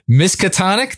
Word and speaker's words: Miskatonic: Miskatonic. Did I get that right? – You nailed it.